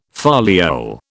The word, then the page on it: foliole: British English